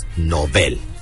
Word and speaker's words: Nobel: Ambas palabras son agudas, por lo tanto, su pronunciación adecuada es Nobel.